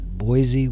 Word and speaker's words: Boise: Most people would look at that, B-O-I-S-E, and they pronounce it Boise with a kind of a Z sound. But the people from Idaho like to pronounce it Boise with more of an S sound.